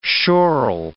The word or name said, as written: schorl